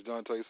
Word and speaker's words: Dontae: So my name is Dante Satchel. – Spell your first name for me, sir. – D-O-N-T-A-E. – D-O-N-T-L-E? – T-A-E.